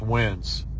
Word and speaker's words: Wens: W-I-N-N-S. Wins? – It's how you spell Wednesday without the day. – Okay, so W-E-N-S. Wins.